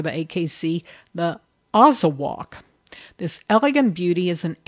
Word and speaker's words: Azawakh: The Azawakh, and that's spelled A-Z-A-W-A-K-H.